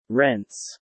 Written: rents

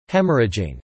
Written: haemorrhaging

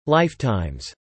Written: lifetimes

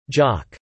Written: jock

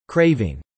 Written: craving

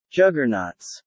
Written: juggernauts

